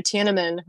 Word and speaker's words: Tiananmen: Tiananmen, right? That's how you say it.